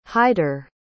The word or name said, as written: Hyder